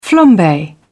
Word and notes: flambe: British English

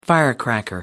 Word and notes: firecracker: American English

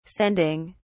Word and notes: sending: British English

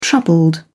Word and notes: troubled: British English

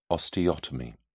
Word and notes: osteotomy: British English

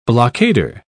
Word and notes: blockader: British English

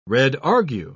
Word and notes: redargue: British English

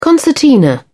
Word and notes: concertina: British English

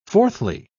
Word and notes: fourthly: British English

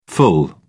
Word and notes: ful: British English

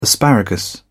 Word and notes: asparagus: British English

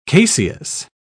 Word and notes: caseous: British English

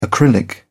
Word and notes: acrylic: British English

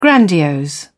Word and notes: grandiose: British English

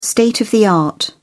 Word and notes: state-of-the-art: British English

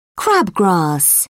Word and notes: crabgrass: British English

